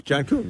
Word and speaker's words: John Kuhn: Did you see what former fullback and teammate of Aaron Rodgers, John Kuhn, is that how you say it? – John Kuhn, yeah.